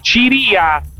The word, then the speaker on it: Ciria: Seguiamo un attimo il corner per il Real Madrid, perché su una palla allontanata da Dragusin, poi Ciria, visto che l'accento è sulla I,